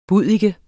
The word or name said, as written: buddike